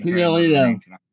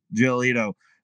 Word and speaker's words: Giolito: Gle- How do you say his last name for the White Sox? I mean, Giolito. Giolito. I mean, he's definitely another Cy Young candidate.